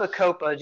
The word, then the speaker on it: Bacopa: It's spelled Bacopa, just so our listeners can look it up if they need to. – Okay. BA. COPA, Bacopa.